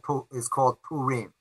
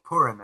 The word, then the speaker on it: Purim: Where the next major holiday the Jews have is called Purim, or Purim as Ashkenazi Jews would pronounce it.